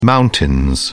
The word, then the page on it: mountains: British English